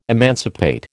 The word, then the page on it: emancipate: American English